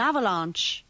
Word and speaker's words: avalanche: Remember to pronounce the second A in avalanche with the sound of the A in arm.